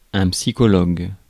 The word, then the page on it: psychologue: IPA: /psi.kɔ.lɔɡ/; French